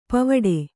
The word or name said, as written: ಪೋವಿಡು